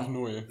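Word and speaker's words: Apnoe: Wie wird das ausgesprochen? – Abneu.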